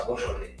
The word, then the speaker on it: Beaujolais: que se escribe como Beaujolais, pero se pronuncia Beaujolais,